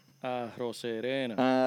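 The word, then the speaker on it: Arozarena: Arrocerena. Arroce, arrocerena, porque es con Z, con Z.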